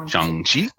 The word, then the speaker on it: Shang-Chi: Shang-Chi. Well, I can't make the vowel sounds quite, so it's like Shang-Chi. Like he does that pronunciation for you. – Shang-Chi. – And it's got it like moves up as you speak.